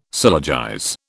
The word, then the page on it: syllogize: British English